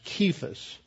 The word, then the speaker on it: Kephas: But it is in Aramaic, it's indicated by a K, so it's pronounced Kephas.